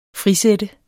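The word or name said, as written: frisætte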